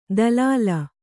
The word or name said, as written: ದಲಾಲ